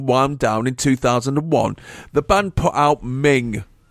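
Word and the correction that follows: Mwng: Did I say that right, Simon? – Mung, I thought it was, yeah. I thought it was, but I didn't say it. – It means mane, like a lion's mane.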